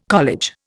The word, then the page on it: college: Indian